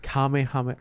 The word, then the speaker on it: Kamehameha: Kamekameka! – Bro, you're saying it wrong. It's like an A. It's just Kamehameha.